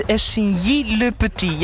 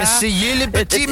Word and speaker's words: Essay Le Petit: Ik moet je toch weer even corrigeren. Het is Essay Le Petit, ja. – Essay Le Petit, merci. – Je moet wel aan de Franse uitspraak werken, hè. Dat is belangrijk.